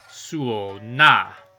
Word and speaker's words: Suona: And I've been mispronouncing the name of this instrument, as usual. I'll try to say it better. It's Suona.